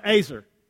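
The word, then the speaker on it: ezer: I think this is NIV, NLT also has helper, but it's actually the Hebrew word, and it's E-Z-E-R, and it's pronounced Azer.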